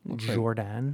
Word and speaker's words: Jourdan: Because his name is spelled J-O-U-R-D-A-N. Jordan. – Looks like Louis Jordan to me. – Why is there a U there? Usually a U indicates that it's said differently.